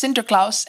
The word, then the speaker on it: Sinterklaas: The Dutch name for St. Nicholas is pronounced Sinterklaas, and voila! A slight phonetic deviation from that gets us to Santa Claus.